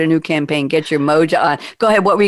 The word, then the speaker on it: moja: But you know what stood out for me was your word, the new word I just learned, Moja. How do you spell that? I know it's a Swahili word, but how do you spell that, Jen, in English? – So the word is M-O-J-A, Moja.